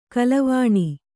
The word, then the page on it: ಗೆಲ್ಲವೆಣ್: gellaveṇ